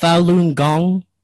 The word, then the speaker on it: Falun Gong: And it's pronounced Falun Gong? – Correct. – Okay, just want to make sure.